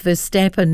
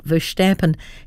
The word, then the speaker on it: Verstappen: It's Verstappen, not Verstappen. He's Dutch, not German.